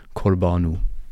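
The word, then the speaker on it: Corbeanu: Es ist Theo, also der Vorname sagt man, äh, spricht man Englisch aus und es ist Corbano.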